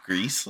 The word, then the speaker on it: Gris: I think you pronounce it Greece, like GRIS.